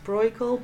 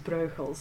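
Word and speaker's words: Brueghel: And I know in this country it's sometimes pronounced Breugel, but I will try not to get carried away with that and pronounce it how I'm used to pronouncing it as Breugel.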